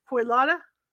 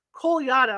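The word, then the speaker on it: Kolyada: Quote, Koyada, that's Koyada, I'm sorry, I mispronounced it.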